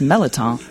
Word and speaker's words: Merleton: So much because it's, you know, it's spelled kind of Merleton, but growing up here, you know, my dad would be like, no, it's Melotón.